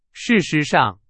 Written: 事实上

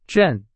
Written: 阵

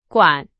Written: 管

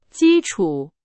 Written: 基础

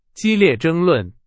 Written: 争论

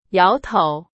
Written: 摇

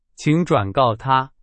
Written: 转告

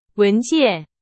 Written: 文件

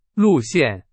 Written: 路线

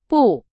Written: 步

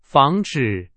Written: 防止

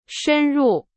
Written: 深入

